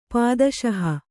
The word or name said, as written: ಪಾದುಶಾಹ